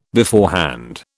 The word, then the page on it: beforehand: British English